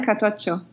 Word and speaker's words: cartoccio: So the last thing I made was from page 230, and that was your recipe for trout al cartoccio. Is that how you pronounce it? – Cartoccio.